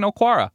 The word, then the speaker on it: Okwara: Julian Okwara, very productive for Notre Dame. Is that how you pronounce his name? – Yep, you got it.